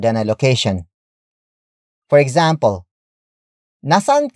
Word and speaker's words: nasaan: The word saan is the root word of another question word, and that is nasaan. Nasaan. Nasaan. Nasaan is spelled as N-A-S-A-A-N.